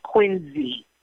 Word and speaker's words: Quincy: They pronounce it Quincy.